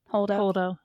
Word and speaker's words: Holdo: Am I saying it right? Holdo. – Yeah.